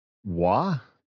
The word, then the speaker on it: Rwoh: She goes by Vern, and last name is Wah. Is that how you pronounce that? R-W-O-H? – Sure.